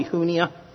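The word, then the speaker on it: Junia: Andronicus and actually, there was no J in the Greek language, so it's probably Hunia.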